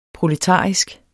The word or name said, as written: proletarisk